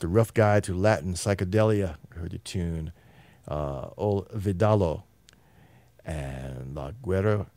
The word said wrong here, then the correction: Lalo Guerrero: How'd you pronounce his name again? I keep mispronouncing his name. – Lalo Guerrero. – Lalo Guerrero.